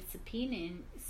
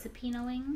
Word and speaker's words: subpoenaing: I can say that Colin Kaepernick is subpoenaing. Did I say that right? – You got it. – Subpoenaing.